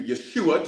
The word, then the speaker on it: Yeshua: Thank you, Yeshua, just so you know the name. It's a Hebrew pronunciation for the name Jesus, all right?